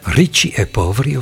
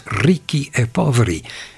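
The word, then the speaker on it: Ricchi e Poveri: niet de bedwetter uithangen, maar ik hoor de naam van deze groep vaak fout uitspreken. Ricci e Poveri of zo. Maar het is wel degelijk in het Italiaans Ricci e Poveri.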